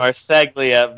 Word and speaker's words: Marseglia: Oh, and by the way, the other members of the Kingdom, are Vinny Marciolago. I know I'm not saying his name right, but nobody does. – Marseglia. Vinny Marseglia and TK O'Ryan.